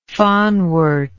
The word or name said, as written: fanwort